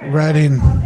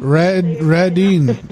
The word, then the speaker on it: Redding: Redding. – What's the last name? – Redding. R-E-D-D-I-N-G. L-M-N-O-P. – Spell it again? – R-E-D-D-I-N-G.